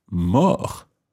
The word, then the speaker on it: mort: It's spelt M-O-R-T. Now, in English, we have words like mortuary, the place where dead people are kept, in a sense. So, mort, M-O-R-T, linked to other words that start with M-O-R-T.